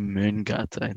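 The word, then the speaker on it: Mångata: And I do think that we were a much better live band in that period of Mangata than before. – I pronounced that wrong as well, actually, just Philippe. – It's fine. – Terrible. – So I think it's actually Mungata in Swedish.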